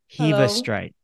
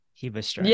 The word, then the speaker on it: Hebestreit: This is episode 67 with Kelly. Hebestreit. Hebestreit. – Yeah, that's perfect. That's the perfect pronunciation.